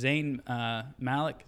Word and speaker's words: Zayn Malik: Yeah, her description for Night Changes is Zayn Malik.